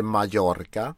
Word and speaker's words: Mallorca: Det heter Mallorca. – Just. – Det är ganska kul att ni vet där ute. Alltså dubbel L uttalas inte. Det är J-ljudet.